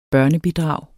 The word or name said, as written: børnebidrag